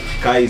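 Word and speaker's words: Kye: Esse Kai se escreve K-Y-E. E Smith com um TH no final.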